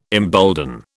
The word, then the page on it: embolden: British English